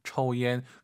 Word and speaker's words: 抽烟: 우리가 초하지 마시고 오유는 어우 발음이에요. 抽 초옌.